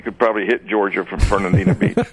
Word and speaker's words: Fernandina: Now, that's Fernandina Beach. Am I saying that right? – Fernandina.